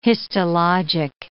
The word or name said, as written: histologic